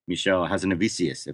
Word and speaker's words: Michel Hazanavicius: They honored, of course, the artist Michel Hazanavicius, if I'm saying that properly.